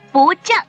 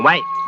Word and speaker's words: 歪: 不正。 – 错。这个字念歪。